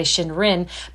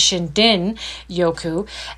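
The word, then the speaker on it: Shindin Yoku (森林浴): So if we read it as English people, we would say Shinrin, but the way they pronounce it is Shindin Yoku.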